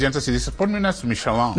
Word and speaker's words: Michelin: Así se pronuncia. – Michelin. – ¿Payaso? – No, sí. O sea, yo digo Michelin. – O sea, pero es las llantas. – Nadie le dice Michelin.